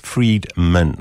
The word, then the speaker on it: Friedman: And this is what I love about Kevin Freeman is that he, sorry, I should say it properly, Friedman, not Freeman, because they sound very similar.